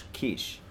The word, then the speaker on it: quiche: Am I saying that right? Quiche? – Quiche? – Quiche.